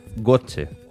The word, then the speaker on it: Goethe: Goethe. Me parece que se pronuncia Goethe. – Así. – Goethe, ¿verdad? Oh. Goethe, vale. Bueno, antes también Marlo ya escribía, ¿no?, de Fausto, pero bueno, cuéntanos el planteamiento de la obra.